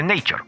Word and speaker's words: Nature: No se pronuncia así. Se dice Nature.